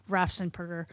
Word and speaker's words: Raffensperger: Well, see, what happened is Greg Palast calls him Raffensperger. Because he keeps purging voters. – I've heard him say his name. Oh, Raffin's Purger. Yeah, okay. – Because he purges voters. So I think that's why that Tom Hartman thinks that's how his name is actually pronounced. – No. Yeah. – And Raffin's I'm like, no. And then when I heard the other election official talk about Raffensperger the other day, then I realized that was how you pronounce his name.